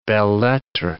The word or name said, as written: belles lettres